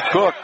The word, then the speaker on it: Koch: Spelled K-O-C-H, pronounced Cook, 6'2", and a junior.